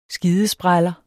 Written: skidespræller

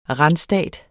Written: randstat

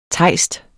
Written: TEJSTER

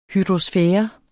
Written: hydrosfære

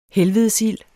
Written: helvedesild

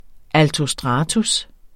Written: altostratus

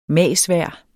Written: magsvejr

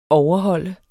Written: overholde

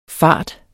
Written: fart

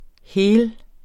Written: hel

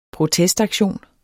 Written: protestaktion

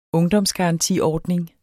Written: ungdomsgarantiordning

